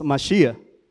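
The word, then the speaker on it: Mashía (מָשִׁיחַ): El término Mesías proviene del hebreo, ni lo voy a decir porque no hablo hebreo, pero la manera de pronunciarlo en nuestro idioma es Mashía, que significa en español el ungido.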